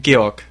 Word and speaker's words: Georg: Yeah, how do you say your name? You say it? – Georg. – Georg. – Yeah. – So your name is Georg, but we will continue to call you George with no E.